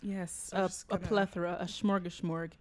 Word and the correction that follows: smorgasbord: Stop saying that word. You can't say it right. – Smorgasbord.